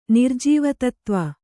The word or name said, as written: ನಿರ್ಜೀವತತ್ತ್ವ